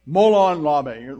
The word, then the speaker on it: Molon Labe: Molon Labe.